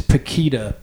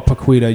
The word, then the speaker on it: Pequita: My last name is Paquita, P-E-Q-U-I-T-A, or Paquita. You can pronounce it both ways.